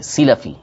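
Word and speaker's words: سِلَفی: مثال کے طور پر ایک حدیث پاک ہے کہ جو حافظ ابو طاہد سلفی رحمۃ اللہ تعالی علیہ، سلفی سین کے زیر کے ساتھ لام کے زبر کے ساتھ، تلفظ یہ اس کا صحیح،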